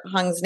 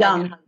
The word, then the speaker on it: Jung: And I'm going to totally butcher Hung's name, but spirituality was termed by, I say Carl Jung, but I think it's Carl Hung. I forget how you say it. J-U-N-G. – Jung. – Jung. – No, Jung. – It's thank you. – Jung. I actually heard it.